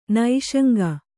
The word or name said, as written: ನಿಃಶಂಕ